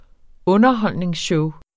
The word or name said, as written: Underholdningsshow